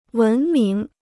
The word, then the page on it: 文明: wén míng